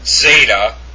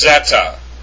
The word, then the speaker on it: zeta: It's not zeta. It's zeta. It's like a DZ. Zeta.